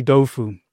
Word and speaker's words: tofu: Tofu, it's actually Dofu in Chinese.